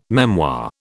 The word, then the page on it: memoir: British English